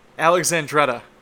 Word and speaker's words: Alexandretta: It's How do you say this? It's Alexandretta. That's it.